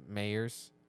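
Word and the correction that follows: Meyers: I think I'm saying his name right. – I think it's Myers. – Is it Myers? – I think so.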